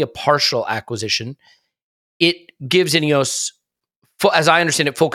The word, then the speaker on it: Ineos: And now uh, the Glazers have sold a portion of their ownership in Manchester United Ineos. Am I saying that right? By the way, I, it occurs Ineos, yeah. Ineos, yes. yep.